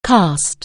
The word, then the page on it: cast: anglais